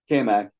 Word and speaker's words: Cammack: Did I pronounce it right? – Cammack, but I answered anything. – Cammack? All right. Yeah, it's like Cammack, but it's with a C.